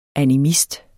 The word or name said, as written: animist